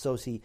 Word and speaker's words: Soucy: who it looks like Saucy, but it's pronounced Soucy.